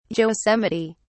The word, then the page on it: yosemite: English; British English